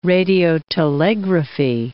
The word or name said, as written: radiotelegraphy